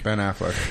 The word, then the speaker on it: Affleck: Is that how you say his name? Ben Affleck? – Ben Affleck.